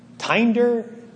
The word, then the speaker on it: Tinder: It's called Tinder. Like kinder, but with a T.